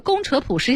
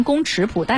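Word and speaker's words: 工尺谱: 是宫扯谱、五线谱还是简谱呢？来给大家也特别要说一下，这个宫扯谱实际上是尺子的尺。 – 对对对。 – 特别也容易念错，念成宫尺谱，但实际上它念宫扯谱，是吧？ – 对对对。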